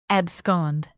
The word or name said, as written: abscond